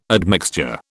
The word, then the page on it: admixture: British English